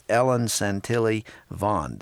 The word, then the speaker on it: Ellen Santilli Vaughn: It's called The Body Being Light in Darkness by Charles Colson with Ellen Santilli Vaughn. Did I pronounce her name correctly? – Yes, you did. That's right.